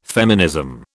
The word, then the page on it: feminism: British English